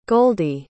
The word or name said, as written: goldie